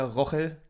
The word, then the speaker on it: Rogel: Roche, irgendwie ja? So, glaube ich, ja. Okay. Also nicht Rogel, sondern der wird südamerikanisch ausgesprochen.